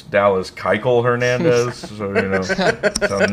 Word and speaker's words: Keuchel: Seeing these Cardinals Keuchel articles. – It's Keuchel, not Keuchel, you fucking Nazi. Actually, that is how you pronounce his name.